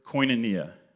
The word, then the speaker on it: koinonia: But if you're taking notes, the Greek word koinonia, it's spelled K-O-I-N-O-N-I-A.